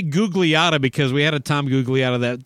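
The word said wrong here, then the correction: Gugliotta: that played for the Timberwolves. – The Minnesota Timberwolves. Yeah. Tom Googliata. It's Gelati. – Gelati. That meant it definitely is spelled more like a gelati.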